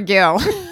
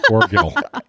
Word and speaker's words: Orgill: And I want to make sure I say Orgel right. It's not Orgel, it's Orgel. – It's or- Orgil. Orgil.